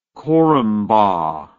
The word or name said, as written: corumba